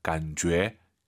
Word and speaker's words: 感觉: 이 제이유이는요, 주애가 아니에요. 너의 죄입니다. 感觉. 이렇게 발음하시기 바랍니다.